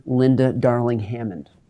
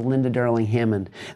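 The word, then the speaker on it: Linda Darling-Hammond: Today, we are going to be talking with Dr. Linda Darling Hammond. I did say that correctly. Dr. Linda Darling Hammond.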